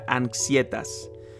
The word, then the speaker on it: anxietas: La ansiedad es eh, o proviene de una palabra eh, de latín que, que se pronuncia anxietas,